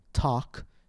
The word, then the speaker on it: talk: For example, in the word salt, S-A-L-T, we do pronounce the L, salt. However, in the word talk, T-A-L-K, the L is silent.